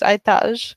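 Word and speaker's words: Aytaç: I keep forgetting that C's are pronounced like J's in Turkish, so I think his actual name is Aytaj.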